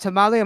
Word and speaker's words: Tamale: Wait, now talk about, is it really tamale, like hot tamale? Am I saying it right? – Yeah, it's tamale. You are right.